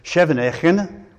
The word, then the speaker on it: Scheveningen: So they had asked them to pronounce that with the Dutch pronunciation, which is Scheveningen, or something like that.